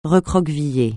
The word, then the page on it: recroqueviller: francais